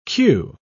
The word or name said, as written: cue